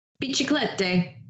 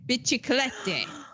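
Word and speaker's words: Ladri di biciclette: Biciclette. Biciclette.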